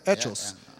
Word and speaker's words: Hechos: Hechos. It says right there. Hechos. Yeah, H is silent.